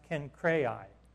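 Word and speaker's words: Cenchreae: And so he says here in verse 1, he says, I commend to our sister Phoebe, a servant of the church, and this is pronounced Kencrai, is how it's pronounced, for anybody who'd like to know.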